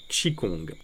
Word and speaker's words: Qigong: Tout d'abord, que veut dire Qigong, qui s'écrit Qi-Q-I et Gong, Kong, G-O-N-G, et se prononce donc Qigong.